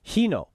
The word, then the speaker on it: Hino: Hino Motors, how do you pronounce this? He asked. Is extremely attractive Japanese audio engineer. Hino. Okay, I see.